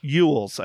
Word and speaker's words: Elwes: So, Carrie Ewels, I think is how you pronounce it. E-L-W-E-S.